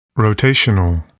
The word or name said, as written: rotational